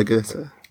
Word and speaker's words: Guidetti: And I never know really how to pronounce his surname, but John Guidetti, Guidetti was it? – Yeah, Guidetti.